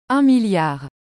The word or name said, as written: 1000000000